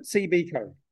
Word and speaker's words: CBCo: That's what it is, is it? CBCo. – That's how they pronounce it.